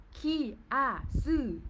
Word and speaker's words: kiasu: The term, the term kia su, pronounced like kia su, okay?